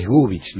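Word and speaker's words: Gubitsch: Es cierto, sí, pero se escribe como se estornuda, con TSH al final. Y aparte el acento es argentino, o sea que es Gubich, no es Gubich.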